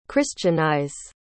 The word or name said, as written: christianize